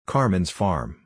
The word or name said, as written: Carmen's farm